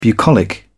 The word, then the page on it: bucolic: British English